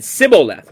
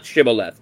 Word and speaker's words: Shibboleth: He said, Sibboleth, not Shibboleth, for he could not frame to pronounce it right.